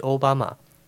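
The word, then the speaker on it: 小浜: 不为别的，小滨的读音就是奥巴马。奥巴马在 2008 年总统大选获胜后，和前任日本首相麻生太郎讲话时也提到了小滨，并表示日后想要拜访。后来小滨市特别赠送奥巴马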